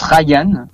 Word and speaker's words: Ryan: Alors ça se prononce Ryan. – Ouais. – C'est vrai que souvent on peut faire la distinction comme ça, parce que moi mon prénom c'est Ryan en fait tout simplement et je voulais garder cette authenticité et je me suis dit que j'allais rajouter juste simplement un N en fait.